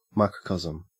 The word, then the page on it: microcosm: Australian English